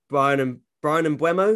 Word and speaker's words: Mbeumo: The only one left is Brian Mbeumo. Is that how you say his name? – Yeah.